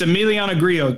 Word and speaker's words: Emiliano Grillo: It's Emiliano Grillo, trust me.